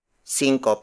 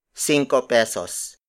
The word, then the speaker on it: cinco: A las cinco is spelled as A-L-A-S hyphen S-I-N-G-K-O. In money matters, cinco, on its own, refers to the 5-centavo coin, unless if it is followed by pesos, as in cinco pesos. Cinco pesos. Cinco pesos. Cinco pesos is spelled as S-I-N-G-K-O space P-E-S-O-S. Again, the Tagalized Spanish word for 5 is cinco.